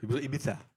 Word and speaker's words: Ibiza: Ibiza. – Maar ik vond Spanje... – Ja, je moet wel goed uitspreken. Ibiza. Ibiza.